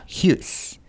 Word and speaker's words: Hughes: Yeah, Hughes.